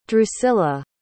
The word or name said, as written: drucilla